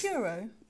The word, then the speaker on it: Puro: It's puro, like pure. Puro.